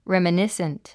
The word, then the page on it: reminiscent: rèmənísnt; レミニッスント